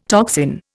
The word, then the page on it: toxin: Indian